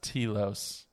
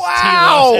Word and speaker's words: Telos: And for those of you that have been listening to The Living Force since its inception, I just want to say it's pronounced Telos. – Oh my god. Oh, it is pronounced Telos. Absolutely.